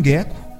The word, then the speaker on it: gecko: É, aí é o seguinte, talvez tenha a ver com gecko, porque é gecko, né? Gecko mesmo, a pronúncia é essa.